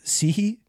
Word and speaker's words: Cihi: His last name is C-I-H-I. So, Sihi, that's it.